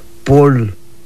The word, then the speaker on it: pol: Cabeza, lo pronunciamos de esta manera, decimos pol.